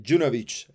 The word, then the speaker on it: Junowick: Hoy estamos aquí con eh, Daniel Junovic, ¿no? ¿Lo digo, ¿Lo digo bien? – Perfecto. Sí, sí, mejor imposible. – Sería eh, Junovic eh, en, en, polaco.